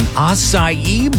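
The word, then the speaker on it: açaí: And yes, I was today years old when I learned it's called an acai bowl.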